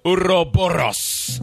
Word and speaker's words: Ouroboros: It's pronounced Ouroboros.